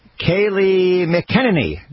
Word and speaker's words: Kayleigh McEnany: At number 7, let me see if I get the name right. Kayleigh McEnany. I got it right.